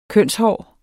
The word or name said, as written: kønshår